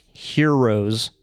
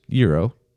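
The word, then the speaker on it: gyros: But you remember when I was promoting Arby's to, they're pronounced heroes for $6? – Euro.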